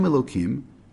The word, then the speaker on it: Elokim: Then it uses a dual name of God, Hashem Elokim, which is the way we pronounce it,